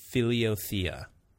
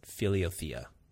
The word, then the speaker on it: Philothea: Philiothea, I think is how you pronounce it. Philiothea. That's how I say it.